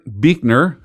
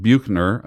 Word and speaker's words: Buechner: Um, Frederick Buechner, who I used to pronounce it Buchner, um, my, one of my favorite authors, uh, is was Brennan Manning.